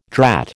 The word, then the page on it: drat: American English